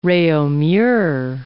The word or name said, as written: Reaumur